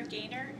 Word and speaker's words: Gaynor: Jennifer Gaynor, G-A-Y-N-O-R, address J.